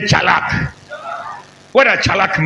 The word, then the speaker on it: tsalach: The word prosper is chalak. Somebody say chalak. Chalak is spelled T S A L A C H.